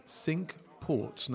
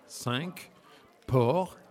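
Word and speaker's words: Cinque Ports: It is also one of the sink ports. Now, I'm using the English pronunciation. The English pronounce it. It's actually a French word. It's supposed to be cinq port. And the English say the sink ports.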